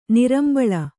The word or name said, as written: ನಾರಿಂಬಳ್ಳಿ